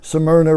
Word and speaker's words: Smyrna: Smyrna, right.